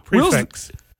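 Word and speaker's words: prix fixe: And so do you say pre-fee, Michaela? – No. – When you talk about the price, the fixed menu. How do you pronounce it? – Prefix. – Prefix. Yeah. – Okay, that's how I'll say it now.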